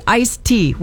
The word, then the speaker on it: iced tea: For the record, it's supposed to be iced tea with a D at the end. How do you say it?